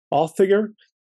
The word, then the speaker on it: Audiger: The correct pronunciation, by the way, is closer to Authiger. It's Old Norse.